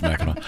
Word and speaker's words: Mackinac: Now, Mackinac. Come on. – It's pronounced Mackinac. Have you ever driven across the bridge? – I have.